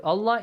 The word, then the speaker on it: Allah: If you got a fatha sound before the word Allah, the letter lam in the word Allah is pronounced heavily, right? With an ah sound.